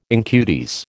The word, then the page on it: incudes: American English